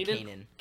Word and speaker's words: Kanan: I always say Kanan. – Kanan, sure. I just saw two A's at the end and I was like, how?